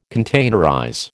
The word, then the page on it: containerize: American English